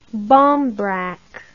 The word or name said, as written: barmbrack